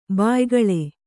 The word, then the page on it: ಬಾಯ್ಗಳೆ: bāygaḷe